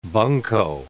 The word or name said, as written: bunko